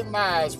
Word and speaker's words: Mize: So when we say Mize, we automatically have to spell it, M-I-Z-E.